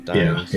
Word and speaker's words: Dyens: You know, D-Y-E-N-S. – I think my teacher always called him Dienz.